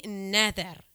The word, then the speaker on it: Noether: Eh, y se llama Emmy Nether. Se escribe Noether.